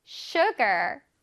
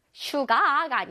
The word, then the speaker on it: sugar: sugar sugar 슈가가 아니라 sugar sugar.